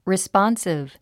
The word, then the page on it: responsive: rispάnsiv; リスポンスィブ